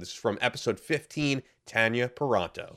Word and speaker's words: Tanya Paronto: And yes, it is pronounced that way. I'm used to saying Tanya for everybody else with the same name spelling, but they will both insist to me it is Tanya Paranto for those keeping score at home.